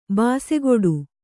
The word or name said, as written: ಭುಸುಗುಡು